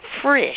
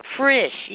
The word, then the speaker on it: Frisch: It's Frisch. – Frisch. – As I was mentioning, Frisch, yes. It rhymes with fish, except that it has an R in it. And I believe it is the German equivalent of our word for fresh.